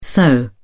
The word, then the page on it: soh: British English